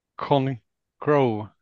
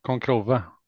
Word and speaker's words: Concrow: Eller ska man säga Concrove? Nej, det är, nog, det, det är nog rätt där som du uttalade med ett långt mellanrum mellan Con och Crow.